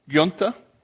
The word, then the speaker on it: Giunta: Giunta.